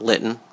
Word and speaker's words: Litten: So I want to jump into introducing Steve and talk about Litten, rhymes with kitten, Litten Savings.